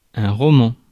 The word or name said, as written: novel